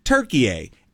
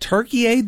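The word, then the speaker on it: Türkiye: So Turkey A is spelled T-U-R-K-I-Y-E. And there's those two dots over the U. All right? And it's pronounced Turkey A.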